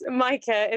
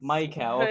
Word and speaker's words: Micah: Close, Micah. It's Micah.